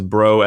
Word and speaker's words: Braud: So my last name is Bro as in B-R-O, but it's spelt B-R-A-U-D.